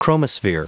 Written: chromosphere

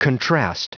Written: contrast

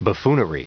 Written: buffoonery

